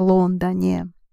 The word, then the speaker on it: Лондоне: Keep the stress on London. В Лондоне.